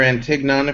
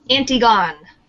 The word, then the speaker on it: Antigone: We're discussing Antigone, or Antignon, if you pronounce it incorrectly, like I originally did. – Antigone.